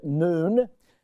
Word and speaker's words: Nun: It's the letter Nun. That's how you say it, Nun.